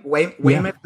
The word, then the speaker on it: Weymouth: And I think it was Weymouth. Am I saying that right? Weymouth, yeah. Massachusetts.